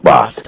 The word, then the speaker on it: Bost: So you have Congressman, is it Bost or Boast? How does he say his name? Congressman Bost. Mike, Mike Bost.